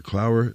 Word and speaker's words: Clower: Remember, Clower rhymes with flower.